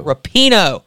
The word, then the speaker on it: Rapinoe: Better say her name right. – So it's Megan Rapinoe. – Rapinoe.